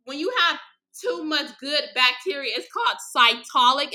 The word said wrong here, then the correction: cytolytic: if I'm not pronouncing that right. It's, hold on, y'all. I gotta search it up to see how to spell it. Because she gave me literally a pamphlet of what it means. Cytolic vaginosis. If I think I'm pronouncing it wrong, it's cytolytic.